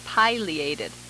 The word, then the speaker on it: pileated: They tend to pronounce its name pileated.